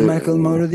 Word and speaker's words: Macklemore: İngiliz pop yıldızı Ed Sheeran, Amerika Birleşik Devletleri turnesinde kendisiyle birlikte sahne alan rapçi Macklemore'u, öyle mi okunuyor? Macklemore. Özgür Filistin açıklaması. Macklemore. Macklemore. – Evet. – Ha, şimdi oldu, evet. Fransızdan İngiliz aksanına geçmek zor oluyor.